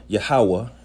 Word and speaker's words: Yahweh: As we continue to read scripture, after Moses' supplication, asking the Lord, Yahweh, as I pronounce his name in Paleo Hebrew, which was the language spoken up until after the time of the Apostle Paul.